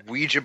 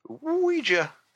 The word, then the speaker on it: Ouija: Ouija board, indeed. – That's how it's pronounced, dude. – I know, It's so stupid. – Ouija.